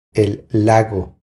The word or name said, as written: El lago